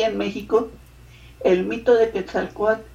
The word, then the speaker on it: Quetzalcóatl: Yo sí me fui a mirar quién era este Quetzalcóatl, ¿cómo lo estoy pronunciando mal, no? – Quetzalcóatl. – Quetzalcóatl.